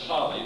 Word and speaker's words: Halle: He eventually founded the famous University of Halle, it's pronounced Halle, near Berlin, and the Pietistic movement sort of emanated from this University of Halle.